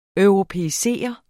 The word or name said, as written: europæisere